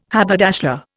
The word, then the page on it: haberdasher: Indian